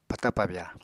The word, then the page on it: pata-pabya: Apatani